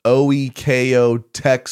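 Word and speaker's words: OEKO-TEX: The Lola pet bed is OEKO Tech Standard 100 certified. Am I saying that right? That's fancy stuff. – That's good.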